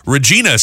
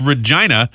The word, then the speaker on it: Regina: I've been going through my life going about my business thinking it was Regina, Saskatchewan. – Uh-huh. – Apparently, it's pronounced by locals as Regina. – Interesting. – As you might imagine, it rhymes with a certain body part that gets people giggling.